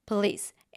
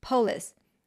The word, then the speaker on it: police: Given that you have found a pronunciation mistake, okay, let's say in my case, the word police, and I've been pronouncing the word as polis.